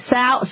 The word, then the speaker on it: Seau: Did I pronounce that right? Seau? Okay, Seau.